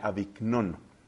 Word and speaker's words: Avignon: Aviñón, que se escribe con GN, Avignon.